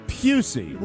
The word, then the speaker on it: Pusey: We have youngest listener, Kevin Pussy. – No. – What? I'm sorry. What? – You have to read the pronouncer. – Oh, I'm sorry. Kevin Pusey, like Gary Busey.